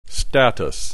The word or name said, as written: status